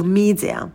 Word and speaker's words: media: Ударение на первый слог. И в первом слоге у нас долгий звук «и». Сделайте вот эту волну. Media.